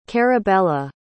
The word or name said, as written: carabella